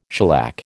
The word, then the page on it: shellac: American English